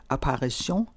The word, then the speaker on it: Apparition: Hans afgørende gennembrud som komponist kom på verdensmusikfesten i Køln i 1960, med det 9 minutter lange værk for stort symfoniorkester Apparition, hvis titel skal udtales på fransk, det har Ligeti sagt, for på engelsk har den en anden underbetydning, mens den på fransk betyder til synekomst, og det er den mening, det skal give.